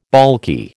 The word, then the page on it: baulky: American English